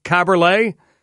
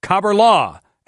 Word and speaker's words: Kaberle: One, Thomas, pronounced his name Kaberle. Frantisek pronounced his name Kaberla.